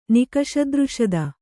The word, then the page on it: ನಿಕಷದೃಷದ: Kannada